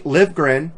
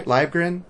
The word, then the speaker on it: Livgren: Livgren, did I say his name right? Livgren? – You said it right.